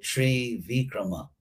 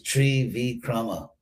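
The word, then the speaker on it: Trivikrama: So Krishna is called Tri Vikrama, which in Western Sanskrit is Trivikram, but actually it's Tri Vikrama, three great steps.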